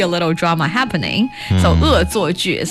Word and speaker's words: 恶作剧: 恶作剧。